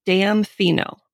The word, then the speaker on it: damfino: okay, your second term is damfino. So I'm going to spell it for you. Okay, please. So it's D A M F I N O. What? Damfino.